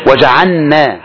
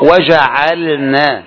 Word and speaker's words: وجعلنا: ايضا من الاخطاء يا اخواني، وجعلنا، الكثير يقرأ وجعلنا فيها، وجعلنا، وهذا غلط. اين اللام؟ راحت اللام، انما النطق وجعلنا، وجعلنا لازم اللام تخرج، وجعلنا.